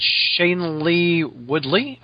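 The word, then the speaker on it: Shailene Woodley: Anyway, to tie it in, there was some pictures of Emma Stone and Shane Lee Woodley, I think is how you say her name.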